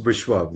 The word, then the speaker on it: Vishwa: It's pronounced Vishwa, V-I-S-H-W-A.